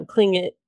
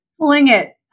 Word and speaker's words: Tlingit: So it, of course, this is very difficult to convey on a podcast, but you can look up Tlingit, which is spelled T as in Tom, L, I, N as in Nancy, G, I, T. So it is not spelled like it sounds. – Which, no, not like Klingon at all.